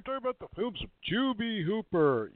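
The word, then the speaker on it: Tobe: Toby Hooper. We're here talking about Toby Hooper. I've heard it mispronounced sometimes as Tubi.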